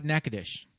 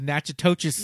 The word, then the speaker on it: Natchitoches: Oh, Natchitoches. – Is that how it's pronounced? – Yeah, that's how it's pronounced.